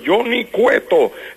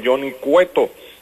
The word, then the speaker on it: Johnny Cueto: Johnny Cueto, eso es facilito, pero los gringos no pueden decir Johnny Cueto, ellos dicen Johnny Cueto.